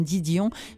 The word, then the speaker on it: Didion: Et très touchant, moi, Madame Didion, je voudrais, parce que vous le prononcez tellement bien à l'anglaise, mais je vais peut-être l'épeler pour les amis qui nous écoutent. Didion, c'est D-I-D-I-O-N, voilà.